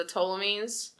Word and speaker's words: Ptolemies: Yeah, because Cleopatra's last name started with a P, and that's silent, wasn't it? It's the Ptolemies. – Yeah, it's P-T-O-L.